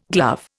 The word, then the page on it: glove: Indian